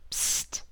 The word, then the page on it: psst: American English